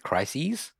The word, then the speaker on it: crises: How do you say that word? How do you say the plural of crisis? – I'm going to go with crises.